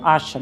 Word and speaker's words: Άσσα: Έτσι λέγεται το χωριό, Άσσα, με 2 σίγμα και προφέρεται κάπως βαριά.